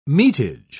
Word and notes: metage: British English

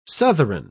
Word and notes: southron: British English

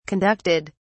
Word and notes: conducted: British English

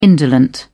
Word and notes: indolent: British English

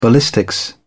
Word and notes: ballistics: British English

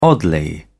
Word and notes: oddly: British English

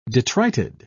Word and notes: detrited: British English

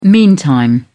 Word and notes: meantime: British English

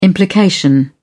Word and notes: implication: British English

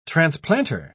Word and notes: transplanter: British English

